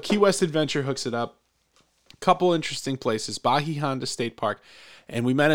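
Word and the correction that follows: Bahia Honda: She said, Bayanda is how you say it. So Bayanda.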